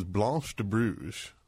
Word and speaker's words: Blanche de Bruges: This is Blanche de Bruges, and that's how you pronounce it.